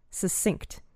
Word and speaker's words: succinct: So I think it's succinct. – Succinct. I think that's right.